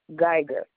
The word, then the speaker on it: Geiger: A is in apple, M is in Mary, Y is in yak, J is in Jack, O is in octopus, Amy Jo, Geiger,